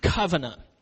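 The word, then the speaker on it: Kavanah: It's spelled K-A-V-A-N-A-H. K-A-V-A-N-A-H. And it's pronounced covenant.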